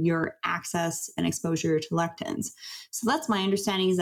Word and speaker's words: lectins: Lectins, yeah.